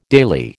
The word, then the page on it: daily: American English